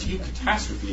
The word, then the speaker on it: eucatastrophe: How do we pronounce it? And that's why people are here, of course. It's not that hard. It's eucatastrophe. It's a good catastrophe.